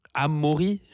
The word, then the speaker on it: Amaury: Amaury, je dis ça correctement? – Amaury, ouais. Bah, ouais, tu le prononces bien.